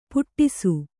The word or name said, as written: ಪುಟ್ಟಿಸು